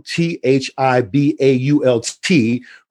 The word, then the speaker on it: Thibault: So I want you guys to say hello and welcome Thibault Cum, T H I B A U L T, pronounced Thibault.